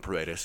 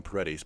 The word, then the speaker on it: Paredes: And I need to tell myself to call him Paredes because that's how it's pronounced. I always want to call him Paredes.